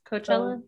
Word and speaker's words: Coachella: Coachella.